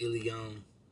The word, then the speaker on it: Aeileon: And then I changed the spelling because I didn't like I-L-L associated with it. So I changed it to A-E-I-L-E-O-N. But if you ask Alexa to say my name, she'll say alien.